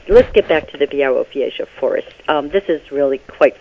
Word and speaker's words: Białowieża: And plus, you forced me to go to, see if I can pronounce this correctly, the Białowieża Forest. I can't pronounce it. – Oh, Białowieża Forest. – Białowieża Forest. There we go.